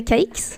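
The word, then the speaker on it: Cayx: Eh, men sommaren spenderar hon ju också på sommarresidenset eh, Gråstens slott eller Chateau de Syx. Sa jag rätt nu Jenny? Nej, Chateau de Caix.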